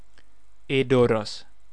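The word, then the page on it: Edoras: Sindarin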